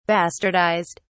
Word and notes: bastardised: British English

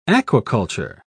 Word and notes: aquaculture: British English

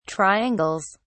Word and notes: triangles: British English

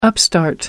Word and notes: upstart: American English

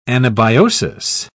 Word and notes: anabiosis: British English

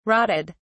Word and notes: rotted: British English

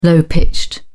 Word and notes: low-pitched: British English

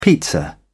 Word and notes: pizza: British English

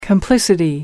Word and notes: complicity: American English

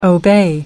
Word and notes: obey: American English

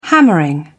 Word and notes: hammering: British English

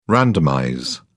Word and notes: randomize: British English

